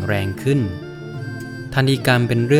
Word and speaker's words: ทัฬหีกรรม: สวัสดีครับพบกับรายการคำวัดวันนี้เสนอคำว่าทันฮีกรรม